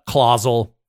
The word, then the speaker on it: Clausel: Jenna Clausel, rhymes with Hazel.